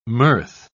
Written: mirth

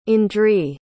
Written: andree

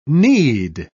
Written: knead

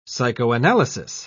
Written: psychoanalysis

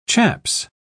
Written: chaps

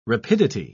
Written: rapidity

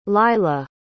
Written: lyla

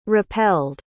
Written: repelled

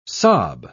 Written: sob

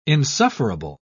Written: insufferable

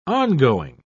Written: ongoing

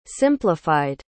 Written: simplified

